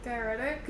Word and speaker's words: diuretic: Diuretic.